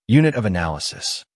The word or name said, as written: Unit of analysis